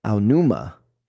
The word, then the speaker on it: Aonuma: And so now how do you say his name again? – Aonuma.